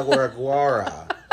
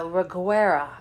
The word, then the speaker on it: Reguera: No, Ana de la Reguera. That was her name as she was born. – Ana de la Guardia? – Yeah, de la Reguera. Am I saying it right? – You know what we're great at? – Being white. – Ana de la Reguera. – Reguera. – And I am sure somewhere it's a Reguera.